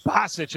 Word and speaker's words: Basich: That looks like it's Basics with the call. Bennett Basics. Basich, maybe. That's his first. Bennett Basich is the pronunciation.